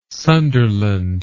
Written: Sunderland

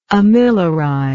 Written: amiloride

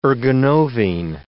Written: ergonovine

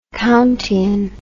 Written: countian

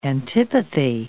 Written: antipathy

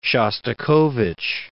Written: Shostakovich

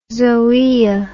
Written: zoaea

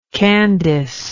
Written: candace

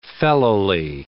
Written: fellowly

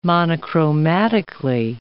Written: monochromatically

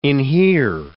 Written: inhere

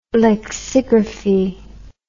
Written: lexigraphy

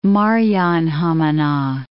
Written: Maarianhamina

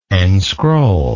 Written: enscroll

